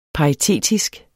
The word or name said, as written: paritetisk